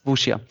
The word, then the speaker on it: Wuxia: Auf der anderen Seite haben sie sich mal mal wieder ein bisschen Cha, jetzt muss ich dich mal fragen, wie spricht man das richtig aus? Wuxia-Genre? – Ja, genau, Wuxia. – Wuxia.